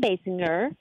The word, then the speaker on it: Basinger: Yes, it was Kim Basinger. Am I saying her name right? – Yes, you are.